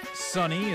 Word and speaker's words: Sonny: Sunny is spelled S-O-N-N-Y.